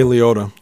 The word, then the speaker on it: Liotta: Henry Hill, uh, you know, who was played by uh, Ray Liotti, uh, or how, how would you say his name? Ray Liotta.